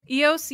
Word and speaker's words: Eos: Eos, yeah, Eos is the goddess of the dawn.